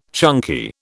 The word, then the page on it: chunky: British English